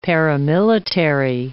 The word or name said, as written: paramilitary